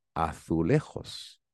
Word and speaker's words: azulejos: By the way, the name of those tiles in Spanish, we call them azulejos, A-Z-U-L-E-J-O-S. It's the same spelling in Portuguese, but they pronounce it azulejos.